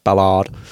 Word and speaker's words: Ballard: Ballard.